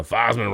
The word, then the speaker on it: Theismann: Uh, Theismann rhymes with Heisman, but I mean, look, man, that that's a that's a rook.